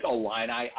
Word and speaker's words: Illini: First of all, it's Alini, I-L-L-I-N-I. So that's how it's pronounced.